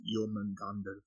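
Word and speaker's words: Jörmungandr: But yeah, uh, the snake itself is um, Yulmangander, uh, with the umlauts, but how you're pronouncing it is without the umlauts. So you're saying it correctly because the umlauts are there, but if you're going to be the Nordic correct pronunciation, it's Jomungandr.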